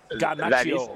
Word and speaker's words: Garnacho: what about the goal by Alejandro Garmocho? – Alejandro. – The, the bicycle goal. – Is, is, that, is, that up, is that up? Am I saying his name correctly? – Garnacho. Is, yeah, Garnacho.